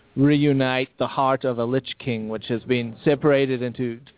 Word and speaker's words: Lich King: I believe it's pronounced Lich King.